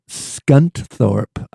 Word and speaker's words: Scunthorpe: But in any case, Howard Andrew Trafford Devoto was born in Scunthorpe. I told you I love English names. Wouldn't you love to be a town that the first syllable is skunt?